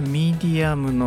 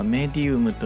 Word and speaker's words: medium: 英語の発音としてはミディアムの方が正しいのですがあ日本語では、まあ、特に美術関係の方はメディウムという発音これはラテン語の発音なの